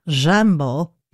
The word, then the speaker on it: Jimbo: This is Jimbo's Lullaby, but that comes from a Parisian pronunciation of the word jumbo, which would be pronounced roughly jambo in French.